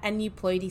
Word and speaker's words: aneuploidy: Today we're going to be talking about aneuploidy screening.